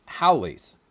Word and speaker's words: haoles: And the word is not, to my eye at least, pronounced how it is spelled. It is spelled H-A-O-L-E-S, and it is pronounced Howley's.